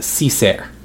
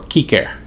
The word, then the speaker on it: cicer: The French got the term from the Latin cicer or kiker, depending on if you choose to pronounce the Latin with the hard C or not.